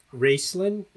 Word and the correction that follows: Roeslein: Is that Raceland Alternative Energy? – Mm, Roeslein, yeah. – Roeslein. I, I, think I pronounced it wrong twice now.